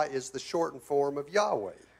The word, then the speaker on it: Yahweh: So even though Jehovah is not how it was pronounced, the fact that it has the four consonants of God in it. – Aunque Jehová no es como se pronuncia en el hebreo cuando tenga esas cuatro letras, es el nombre de Jehová. – It is the name of God. – Es el nombre de Dios. – But if you want to pronounce it with the Hebrew vowels the proper way, it would be closer to Yahweh.